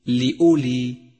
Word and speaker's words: لأولي: خامسا تأمل الواو الرمادية في كلمة لأولي لأولي، فإنها لا تلفظ، وقد ميزها مصحف التجويد بهذا اللون الرمادي إشارة إلى عدم لفظها لأولي وليس لأولي، فهو خطأ شائع.